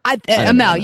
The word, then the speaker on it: Amell: Amell. – Amell, yes.